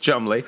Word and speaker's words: Cholmondeley: Yeah, spelled Chumundley, and this is pronounced Chumley.